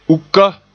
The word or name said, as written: uka